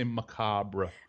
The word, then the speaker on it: macabre: We say macabre. – Macabre. – We just kind of leave out the extra syllable.